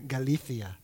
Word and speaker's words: Galicia: That's how you say it if you're in Spain, Galicia, which is rumored to be the place where the bones of the apostle James found their final resting place.